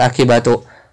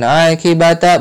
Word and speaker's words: akibatu: Lihat akibatu. Akibatu di sini fa'il. Fa'il itu isim marfu. Akibatu ini isim marfu. Ya, makanya dibacanya akibatu, bukan akibata, bukan akibati.